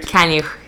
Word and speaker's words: Kaniuch: Did I say it right? – Kaniuch, yeah.